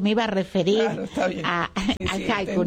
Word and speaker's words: haiku: Haiku, incluso ahora, claro, porque nosotros la H no la pronunciamos. No. Nosotros tendríamos que decir haiku.